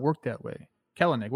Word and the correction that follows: Kelenic: What do I keep saying? – It's Kelnick. – Kelnick. – Two syllables. – Ignore that second E when you're talking about him.